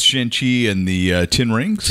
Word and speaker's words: Shang-Chi: Shang-Chi.